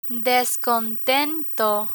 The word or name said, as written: descontento